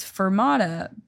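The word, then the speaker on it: Furmata: To make the name of mine worse, it's Fermata, but it's spelled with a U, like F-U-R, because the mascot is a husky.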